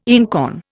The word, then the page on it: einkorn: Indian